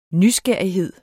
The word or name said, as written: nysgerrighed